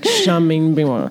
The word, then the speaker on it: Charmaine Bingwa: I think I cross-pollinated with my last name, like Charmaine Bingwa.